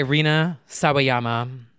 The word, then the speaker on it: Rina Sawayama: Lucid by Rina Sawayama.